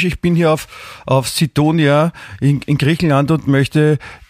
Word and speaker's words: Sidonia: ich bin auf Griechenlands Sidonia wird das ausgesprochen, okay.